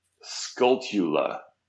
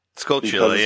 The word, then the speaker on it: Skulltula: I realized it's pronounced Skulltula. – Skulltula, yeah, like tarantula. – It's supposed to be like tarantula.